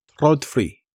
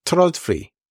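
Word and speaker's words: TRÅDFRI: I have these little IKEA, a listener sent me the correct pronunciation, Trodfri. So please tell me, Mr. Listener, if I got that right, the Swedish, you know, the Could you say it again? – Trodfri, something like that.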